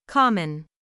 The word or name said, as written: common